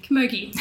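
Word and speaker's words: camogie: Is that how you say it? Kamaji? The female hurling. – Kamogi. – There we are.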